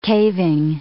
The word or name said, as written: caving